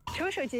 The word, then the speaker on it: 处暑: 处暑节气到，盛夏迎来了终章。处是一个多音字，当它读三声的时候，寓意着终结、潜藏，所以处暑节气也意味着暑热的终止。 – 处暑